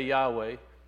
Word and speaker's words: Yahweh: If it's Y-H-V-H, where do we get the W? Because in classical Hebrew, that's modern Hebrew. In classical Hebrew, it would be a W because it's Wow, not Vav. And so that's where Yahweh came in, by putting the E vowel sound and the A vowel sound in there, you get what? Yahweh.